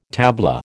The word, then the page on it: tabla: American English